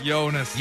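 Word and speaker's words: Joonas: He instructed me properly on how to say this, but Jonas. Close? – Jonas.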